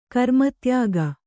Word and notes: ಗ್ರಾಮೋದ್ಯೋಗ: grāmōdyōga

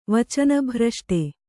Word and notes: ವಚನಭ್ರಷ್ಟೆ: vacana bhrashṭe

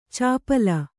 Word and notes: ಚಾಪಲ: Kannada

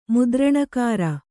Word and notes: ಮುದ್ರಣಕಾರ: Kannada

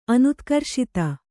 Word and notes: ಅನುತ್ಕರ್ಷಿತ: kannada

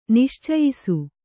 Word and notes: ನಿಶ್ಚಯಿಸು: niścayisu; Kannada

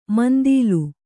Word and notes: ಮಂದೀಲ: mandīla; Kannada

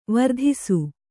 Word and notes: ವಿರಾಧಿಸು: Kannada

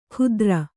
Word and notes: ಕುಧ್ರ: kannada

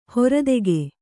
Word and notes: ಹರಿತಕ: haritaka

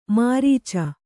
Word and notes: ಮುರಿಚು: muricu

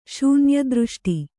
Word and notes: ಶೂನ್ಯದೃಷ್ಟಿ: Kannada

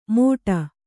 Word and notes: ಮೋಡ: Kannada

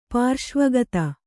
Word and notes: ಪಾರ್ಶ್ವಗತ: kannada

